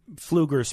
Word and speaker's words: Pfluger: The way, Pfluger spelled P-F-L-U-G-E-R. There's a silent P in there, pfluger.house.gov.